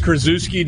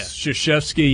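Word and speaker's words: Krzyzewski: Is that like a Coach K thing where they just pronounce their name a different way to make it easier on everybody? – Because Krasuski doesn't really make as much sense as yeah. Shashevsky.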